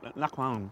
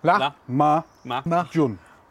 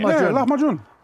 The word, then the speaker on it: lahmacun: I always say lakwan, but that's probably wrong, isn't it? I sound like I'm trying to clear my throat. – You went in like really excited with it, and then was like, oh, I've got this wrong. – So much, kind of, I caught your eye midway through the word. How should I be saying it? How do you pronounce it? – La-ma-jun. – Oh, I was overcomplicating it.